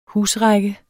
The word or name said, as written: husrække